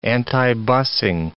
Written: antibusing

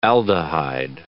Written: aldehyde

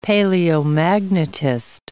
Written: paleomagnetist

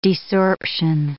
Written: desorption

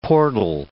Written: portal

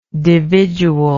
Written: dividual

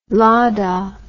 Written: Lada

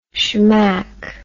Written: shmack

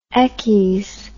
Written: eckies

